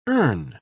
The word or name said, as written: urn